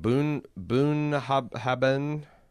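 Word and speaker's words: Bunnahabhain: Boonahabben.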